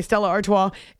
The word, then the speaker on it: Stella Artois: Okay, Stella Artois.